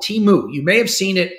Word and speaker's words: Temu: It's pronounced Temu.